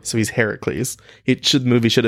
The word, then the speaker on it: Heracles: Hercules is actually the Roman pronunciation of the name. – Heracles. – Heracles is the Greek.